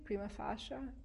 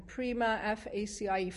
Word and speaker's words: prima facie: If there were even 10 righteous people in each city, how do you pronounce this? Prima facie, prima facie, Latin, prima facie, face value.